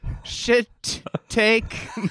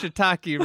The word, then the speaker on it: shiitake: And I was like, and then could I get a shit take mushroom salad? Needless to say, I'd never have pronounced shiitake wrong since.